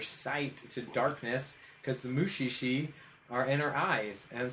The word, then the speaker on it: Mushishi: It's Mushishi. M-U-S-H-I and then S-H-I.